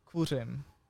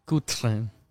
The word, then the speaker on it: Kuřim: Uh, let's try it slowly. – Oh yeah. pronounce it slowly. – Kuřim. Kuřim. – yeah, it's the r sound.